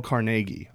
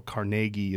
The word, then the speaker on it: Carnegie: Apparently, though, Carnegie is not pronounced Carnegie; it's Car Carnegie Carnegie, like Carnegie Hall.